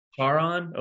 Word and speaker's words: Charon: And I had the Pegasus horse, and I did not have the giant Kraken, but I had was it Karan, the boatman? – Oh, Charon. – Charon? Okay, I couldn't remember. It was a hard C or not.